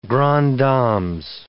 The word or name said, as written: grande dames